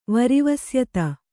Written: ವರಿವಸ್ಯತ

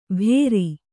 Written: ಭೇರಿ

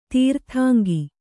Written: ತೀರ್ಥಾಂಗಿ

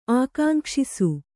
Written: ಆಕಾಂಕ್ಷಿಸು

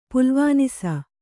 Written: ಪೊಲವಾನಸೆ

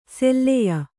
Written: ಸೆಲ್ಲೆಯ

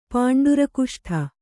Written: ಪಾಂಡುರಕುಷ್ಠ

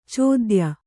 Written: ಚೋದ್ಯ

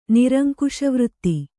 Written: ನಿರಂಕುಶವೃತ್ತಿ

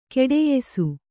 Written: ಘಟಿಯಿಸು

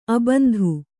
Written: ಅಬಿಂದು

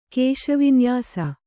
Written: ಕೇಶವಿನ್ಯಾಸ

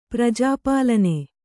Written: ಪ್ರಜಾಪಾಲನ

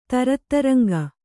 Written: ತರತ್ತರಂಗ

